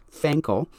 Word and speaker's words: fankle: Fankel, like ankle, but with an F at the beginning. F-A-N-K-L-E.